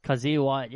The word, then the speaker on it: Kyzir White: Kyzir, Kyzir White? – Kyzir White, yeah.